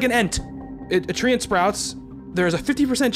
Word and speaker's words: treant: Did you say tree ant? – Yeah, tree ant. It's spelled T-R-E-A-N-T.